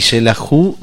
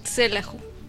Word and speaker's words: Xelajú: Y Selahu se escribe con X. – Con X. – Selahu. – Selahu. Selahu.